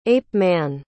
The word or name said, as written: ape-man